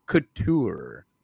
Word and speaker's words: Couture: and Randy Couture.